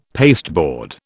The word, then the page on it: pasteboard: British English